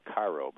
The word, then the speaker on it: Cairo: It's spelled Cairo, but it's pronounced Cairo, Illinois, down in the southern tip of the state.